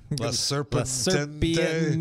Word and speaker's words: La Serpiente: What is it? – La Serpiente. – Yes, that's exactly La Serpiente. – It. That's exactly how you pronounce it.